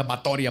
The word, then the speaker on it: Báthory: La condesa Erzbet Batori de Exed, porque le dice, se pronuncia Batori aparentemente, nació en Nibrator, en Hungría, en lo que ahora se conoce como Eslovaquia y Rumania, el 7 de agosto de 1560.